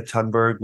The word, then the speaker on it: Thunberg: Let's say you made a very good case for why Greta, I didn't know that you didn't pronounce the H, why Greta Thunberg was not an effective spokesgirl, an effective spokes adolescent.